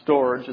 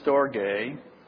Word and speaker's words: storge: The first one is not storage, it's pronounced storge.